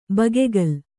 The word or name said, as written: ಬಗೆಗಲ್